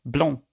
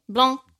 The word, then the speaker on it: Blanc: Det är från eh, märket Kronenborg och jag tror det ska uttalas på franska. Blanc. – Blanc.